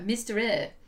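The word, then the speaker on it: Mystere: It's like a French spelling of mystery, but it's like Mysterie. – How is that spelled? – Look, Mysterie.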